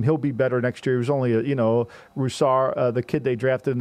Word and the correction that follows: Rousseau: Greg Roussard, I think his name. I always mispronounce his name. – Gregory Rousseau. – Rousseau, you know, thank you, fam.